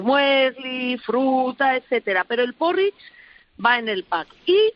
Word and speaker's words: porridge: Porridge se escribe, porridge. Como el porrón, pero porridge.